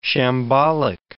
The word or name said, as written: shambolic